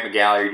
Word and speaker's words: McGalliard: My name is Grant McGalliard. You can find me on Twitter at Grant McGalliard. It's spelled like it sounds with all those vowels.